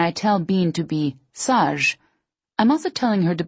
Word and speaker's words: sage: In French, sage rhymes with the Taj in Taj Mahal.